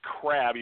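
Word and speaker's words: Crabbe: The Nets are dealing Allen, I believe it's pronounced Crab, even though it's C-R-A-B-B-E, the number 17 pick in 2019.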